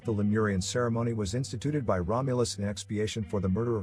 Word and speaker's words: Romulus: In Latin, their names are pronounced with the accent on the second syllable, Romulus and Remus.